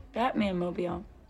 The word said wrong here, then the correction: Batmobile: You're putting the there's no man, it's just Batmobile.